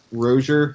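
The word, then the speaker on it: Rosier: But Malik Rozier, apparently, is how you actually say his name instead of Rozier.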